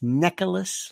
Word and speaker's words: Necheles: But what was really good, which was terrific, is that his defense lawyer, by the way, they're very good, Susan Necheles, N-E-C-H-E-L-E-S, I think it's pronounced Necheles, like Necheles.